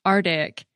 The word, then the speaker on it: arctic: The last word I'll draw out for examination is arctic, pronounced arctic. It's possible that the first C might not be heard in rapid speech, even if a speaker's articulating it. That's because it's produced farther back in the oral cavity than the following T, and so its release can be masked.